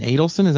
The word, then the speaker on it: Adelson: This is during the time that Sheldon Adelson, am I saying his name right? – Yeah.